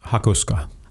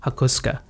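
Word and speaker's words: Hakosuka: So this car, I was looking at is it the Hakusuka? – Hakusuka. – Hakusuka. Yeah. Okay. You is silent.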